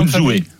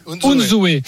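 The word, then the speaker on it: Unzué: Comment on prononce son nom Unzoué. Unzoué. Eusebio Unzoué qui a annoncé qu'il fallait.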